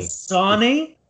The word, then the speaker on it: Dasani: I've either drank Danzina water all day today or milk. – What the fuck is Danzina water? – I'm probably not pronouncing it right, but Dasani. – Dasani? – Yeah, you Dasani.